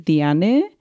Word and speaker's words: Diane: So um, I'm going to be talking about Diane, and I think I read an article that she pronounces it Diane, because it's actually oh. – like to say it in like split in three syllables.